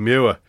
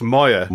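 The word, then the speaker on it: Moir: And it's proven by you because you say Jim Muir. It's Moyer. – Moyer. – No one can pronounce it.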